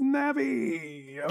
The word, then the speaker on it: Navi: I do look at the Japanese pronunciation, and it's Navi.